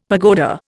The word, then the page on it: pagoda: Indian